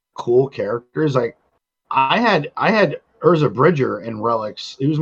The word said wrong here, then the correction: Ezra: Did you say Urza Bridger? – Ezra. Oh my god, what is it with your pronunciations? – I've never watched Rebels.